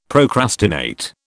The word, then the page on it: procrastinate: British English